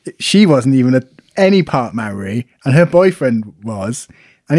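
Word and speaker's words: Māori: I was told, pronounce it like it's almost the word mouldy. – Maori.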